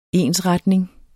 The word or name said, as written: ensretning